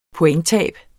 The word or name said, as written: pointtab